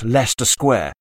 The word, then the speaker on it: Leicester Square: Leicester Square, pronounced Leicester Square, is in London's West End.